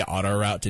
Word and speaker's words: autoroute: I say auto route. – Wait, that's not how it's said. – It's pronounced autoroute. Is how you say it.